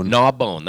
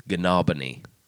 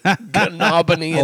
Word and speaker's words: Gnaw Bone: G-N-A-W, and bone. And they pronounce it gnaw bone. That's what I thought it was. And someone said, that's not how you say it. And I'm like, how would you say gnaw bone? And they're like, it's Gnawbony. That's Gnawbony, Indiana.